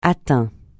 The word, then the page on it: atteint: francais